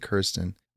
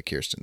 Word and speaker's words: Kirsten: This is one of those things where you see it spelled a million times, and then if you don't listen to interviews, you just don't know how to say it. But yeah, Kirsten, that makes sense.